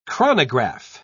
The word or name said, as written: chronograph